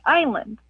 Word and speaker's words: island: Why is it island always spelled like island, and why do we always pronounce it like island? – Oh, what a good question. Why does island have an S in it, and why is it pronounced with a long I?